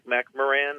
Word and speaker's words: McMoRan: I never remember how they pronounce that one. – McMoRan. – Oh, is it McMoRan? Okay, there's the third. Yes, that sounds right.